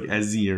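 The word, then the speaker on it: Eze: It's like Ezzy or something. But it's spelled E. The letter E, the letter Z, the letter E.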